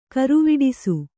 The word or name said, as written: ಘರವಟ್ಟಿಸು